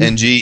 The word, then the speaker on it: Ng: Is it Ng? Would you pronounce that Ng? Joe Ng? – Ng, yeah, Ng.